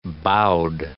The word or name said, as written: bowed